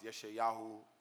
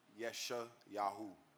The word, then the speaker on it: YE$HAYAHU: All right, so look, my name is YE HAYAHU, right? And my real name is Isaiah. So if you translate that into Hebrew, you know what I mean? It's pronounced YE HAYAHU, and the definition is God is salvation.